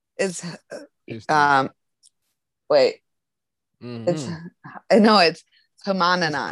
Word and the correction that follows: Hanuman: Hanuman. That's it. I always say it wrong.